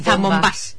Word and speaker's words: zambombas: porque al final va a haber zambombadas. Uy, lo he dicho mal. Zambombas. Aquí hay que decir, no tan fino.